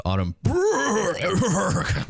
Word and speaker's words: Autumn Burke: I believe her name is pronounced Autumn Burke.